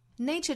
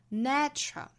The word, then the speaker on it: nature: Nature 这个词不知道很多同学为什么会把它读成 nature，注意是双元音，应该是 a 的发音 nature。